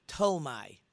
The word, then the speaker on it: Ptolemy: The name of the kings in the Southern Kingdom was Ptolemy. It's actually spelled P-T, but that P is not pronounced. P-T-O-L-M-E-Y, I believe.